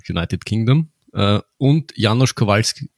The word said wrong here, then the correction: Kowalczyk: Kowalski, de, Kowalczyk. – Kowalczyk, na, eh fast.